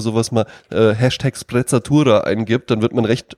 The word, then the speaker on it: Sprezzatura: Und der Begriff ist ja Sprezzatura, ja? mit zwei Z, ein italienisches Wort. Ja? Und, ähm, und einem spitzen S. – Äh, genau, Sprezzatura spricht man es aus, ja.